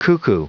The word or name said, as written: cuckoo